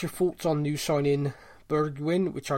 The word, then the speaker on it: Bergwijn: Because I've watched a little video of him on Twitter, and it says, for our benefit, it's pronounced Bergwijn. So think Bergwijn. Half burger and then wine. Bergwijn.